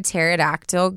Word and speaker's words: pterodactyl: Why can't you hear a pterodactyl go to the bathroom? – You know it? – No. – Because the P is silent.